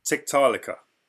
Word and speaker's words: Tiktaalika: Tiktaalika.